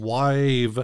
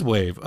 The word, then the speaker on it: WAV: I included an M4A and Wave. I always want to say Wav because that's how the British say it. – I always say Wave. – It is Wave.